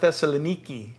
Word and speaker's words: Thessaloniki: They call it Thessaloniki in Greek.